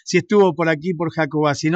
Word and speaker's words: Jacobacci: Yo le digo Jacobacci porque se pronuncia así.